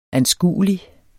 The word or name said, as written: anskuelig